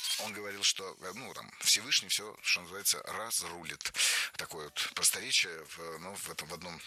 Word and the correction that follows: разрулит: Разрулит, неверно, правильно, разрулит, разрулим, разрулишь. Опять же, ударение ставим за корнем.